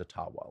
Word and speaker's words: Ottawa: It's like, no, actually, sorry, white Canada, but it really is pronounced Ottawa. Like, that's the Ojibwa pronunciation.